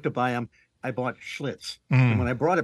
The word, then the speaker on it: Schlitz: Yeah, I think it was Schlitz rhymes with you know what.